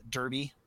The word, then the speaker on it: Derby: That Old Firm derby out there in Scotland. – It's pronounced Derby, but I'm gonna call it Derby.